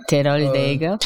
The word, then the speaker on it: Teroldego: No, I mean, like, how do they pronounce it? – Teroldego? – Yeah, Teroldego. – Teroldego. – nice, nice.